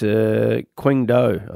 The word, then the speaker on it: Qingdao: Yes, Qingdao, I think it is.